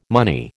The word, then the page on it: money: American English